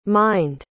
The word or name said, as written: mined